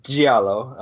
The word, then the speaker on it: giallo: So giallo is pr- uh, pronounced uh, giallo. I hope I'm still saying that right. Uh, or the plural gialli.